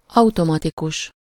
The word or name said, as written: automatic